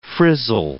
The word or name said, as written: frizzle